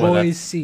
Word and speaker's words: Boise: Boise. That's how people in Boise pronounce it. – That's how you say it. It's Boise.